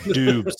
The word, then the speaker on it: Doubs: Like, Christian Watkins looked like a stud Is that dude mispronounces his own name because it's Doobs.